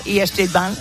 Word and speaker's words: E Street Band: Tres días en Madrid, el día 12, mañana 12, 14 y 17 de junio, conciertazos de Bruce Springsteen y la D Street Band, no sé si lo he pronunciado bien, Marco, y eso, E Street Band, que bueno, la verdad es que es un lujazo tenerle aquí en España.